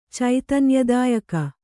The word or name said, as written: ಚೈತನ್ಯದಾಯಕ